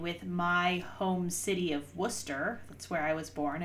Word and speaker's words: Worcester: I can't remember if he said Worcester, which is incorrect. Mm-hmm. I mean, they're both incorrect, or Worcester, but those are the common ones.